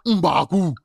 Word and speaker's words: M'Baku: Come on, that's a M'Baku. That's how they said it. You gotta be in the throat, you know.